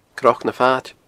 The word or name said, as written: cnocnafaid